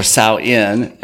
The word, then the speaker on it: Samhain: That pretty much was talking about Samhain, or Samhain, as it's correctly pronounced, and how that all came about, and the veil that's so thin during this time.